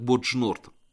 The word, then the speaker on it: Боджнурд: Название Боджнурд в старинных книгах приводится как Бузенджурд. Это арабизированное название слова Бижангерд, с некоторым изменением произносится как Боджнурд.